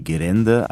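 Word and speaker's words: Gerände (ゲレンデ): Ausgesprochen wird das eher wie Gerände, also R für L, und das bedeutet tatsächlich Skipiste.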